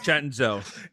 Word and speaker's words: Cenzo: Cenzo. – It's Cenzo. – C-E-N-Z-O, spelled Cenzo, pronounced Cenzo. – Or spelled Cenzo. Either way, however you want to say it. But C-E-N-Z-O.